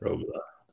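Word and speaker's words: Roglič: It's pronounced Roglu. Roglu? Rogla? – Rogla? I'm reading that as Rogla, right? – Rogla.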